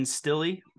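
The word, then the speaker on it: Stille: I'm probably going to butcher how to pronounce his name, but Ben Stilley, the guy that, you know, the defense tackle on the practice. – You nailed it.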